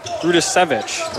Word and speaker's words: Rudisevich: There's a lot of letters. Rudisevich is the pronunciation.